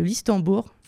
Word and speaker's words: Listembourg: Sur Twitter est né un nouveau pays, le Listembourg, je ne sais même pas si je le prononce bien. – C'est ça.